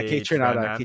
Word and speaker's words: Kaytranada: Like shout out to uh K Tran. Was it? – Yeah, Kaytranada. K Tranada.